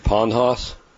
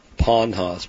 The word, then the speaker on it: Ponhaus: You ever eat pond hoss? – I don't know what that is. – Oh, look it up. – What's it called? – Pond hoss. P-O-N-H-A-U-S.